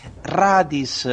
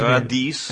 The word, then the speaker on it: Radis: Radis. – Questo invece ha sbagliato qui mai sbagliato l'accento tonico. Questo è Radis.